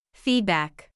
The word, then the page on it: feedback: feed ・back